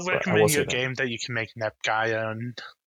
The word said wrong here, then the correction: Neptunia: And also, no, just buying the actual Neptunia game is cheap.